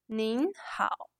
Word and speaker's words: 您好: which is 您好.